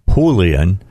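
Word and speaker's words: Julián: Let me pronounce it exactly as he is asked. Julian, okay.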